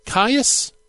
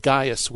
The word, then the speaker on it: Caius: And that is Caius, the Presbyter of Rome. Sometimes it's Gaius with a G instead of a C, a hard C, just FYI.